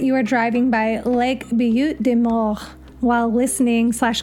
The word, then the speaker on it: Butte des Morts: Now, if you've noticed the way I've been pronouncing Butte des Morts, it's because it was given by French colonial settlers, most likely in the early 1700s.